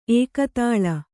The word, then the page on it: ಏಕತಾಲ: ēkatāla